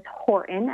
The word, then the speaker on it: Horton: And so my name is Pamela, spelled with two L's, and my last name is Horton, as in Horton Hears a Who, and please feel free to Google or search me out on Facebook there and join me.